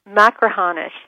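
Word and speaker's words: Machrihanish: Is it Macrahanish? – Macrahanish, yeah.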